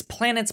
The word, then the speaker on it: Planetes: I want to recommend an anime called Planets, I believe is how you pronounce it. It's Planets, but it's spelled Planetes at the end.